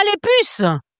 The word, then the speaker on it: les puces: They've got Puce, P-U-C-E-S. How do you say that? Puce? – Ah, les puces. Les puces.